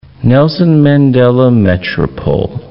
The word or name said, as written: Nelson Mandela Metropole